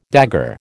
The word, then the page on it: dagger: American English